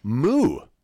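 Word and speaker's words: Mu: The openers include Summering and Gang Signs, but it's actually an album release of the duo Moo, which is spelled M-U.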